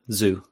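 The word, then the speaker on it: Zhou: But I about Zhu, is that how you say his name? – Yeah, Zhu.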